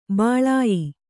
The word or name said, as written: ಬಾಲೇಯ